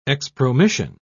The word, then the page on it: expromission: British English